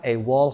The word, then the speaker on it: AWOL: A-W-O-L که تلفظ میشه AWOL، AWOL absent